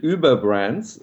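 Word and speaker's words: Über: So actually, since we're both German, for once we can pronounce it Uber Brands, which usually we don't.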